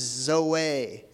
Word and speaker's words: zoe: It's spelled Z-O-E, but it's pronounced Zoe. Zoe with like an A.